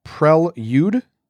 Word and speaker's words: prelude: Prelude is incorrect. The proper pronunciation is prelude.